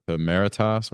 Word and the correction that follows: Mauritius: Yeah, I think it's Mauritius.